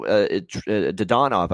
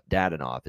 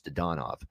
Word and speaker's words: Dadonov: Dadonov, you know, Dadonov, I should say, they keep butchering that name on television. Everyone on television calls him Dadonov. It's Dadonov.